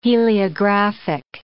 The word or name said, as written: heliographic